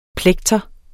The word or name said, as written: plekter